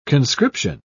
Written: conscription

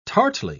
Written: tartly

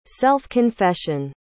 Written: self-confession

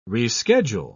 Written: reschedule

